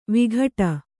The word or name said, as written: ವಿಕಟ